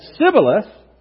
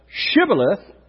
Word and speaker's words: Shibboleth: It says if he said Sibboleth instead of Shibboleth, because he couldn't pronounce a word correctly, they seized him and they killed him at the fords of the Jordan.